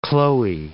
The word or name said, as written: Chloe